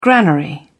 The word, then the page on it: granary: British English